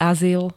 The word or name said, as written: azyl